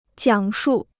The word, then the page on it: 讲述: jiǎng shù